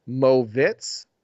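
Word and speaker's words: Moritz: So I guess it Mortiz Wagner is the way it's spelled, but his name is pronounced Movitz Wagner for the Orlando Magic.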